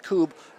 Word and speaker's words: Kubb: It looks like Cub. K-U-B-B is pronounced Coob.